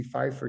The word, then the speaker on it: Pfeiffer: And so it's so interesting what you just said about Pfeiffer, Dee Dee Pfeiffer, you said, did I say her name correctly? – Yeah. Correct. Yeah.